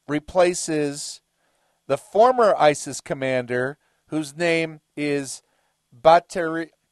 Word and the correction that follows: Batirashvili: It's difficult to pronounce these names. Batirashvili. No, there's an I at the end. Batirashvili.